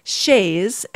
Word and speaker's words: chaise: You'll also hear two different pronunciations of the first word, spelled C-H-A-I-S-E. And Rebecca, I think actually we have used both pronunciations. One is chaise and one is chaise.